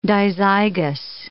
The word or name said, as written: dizygous